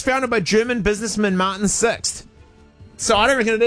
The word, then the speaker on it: Sixt: It's pronounced Sixt. It is pronounced Sixt in German, starting with a Z sound.